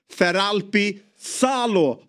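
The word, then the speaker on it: Feralpi Salò: Ny tränare, seger direkt och enligt vår gode och vise Borrell kommer andra raka segern här hemma mot Feralpi Salo har de ska de tydligen uttalas.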